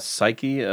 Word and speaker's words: Psyche: Is that how you pronounce it? – Psyche? Let me see. Psyche. Yeah.